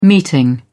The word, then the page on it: meeting: British English